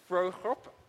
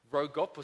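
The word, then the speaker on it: Vroegop: Mark Frohrup, that's how you'd say his name in South Africa. In America, you'd probably say Rogop or something, says, "Lament is a prayer that is birthed in pain and agony and leads to trust."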